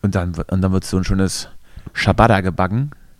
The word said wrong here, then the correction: Ciabatta: Oder Ciabatta heißt das, glaube ich. Richtig ausgesprochen.